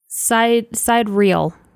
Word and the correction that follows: Sidereal: No, it's Sidereal. – Sidereal Confluence. – That's it. Sidereal Confluence.